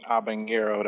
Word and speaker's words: habanero: But that H is silent, though, in habanero, though.